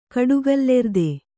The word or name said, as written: ಕಡುಗಲ್ಲೆರ್ದೆ